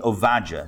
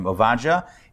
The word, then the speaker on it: Ovadia: There was a prophet named Ovadia. It's his Hebrew name, Ovadia. In English, I think it's pronounced Obadiah. I think that's the English way of pronouncing it.